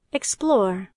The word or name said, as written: explore